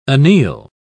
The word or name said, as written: anneal